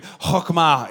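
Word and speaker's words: chokmah (חכמה): You got to say it like you're clearing your throat. Everybody say it to me on the count of three. One, two, three. Chokmah.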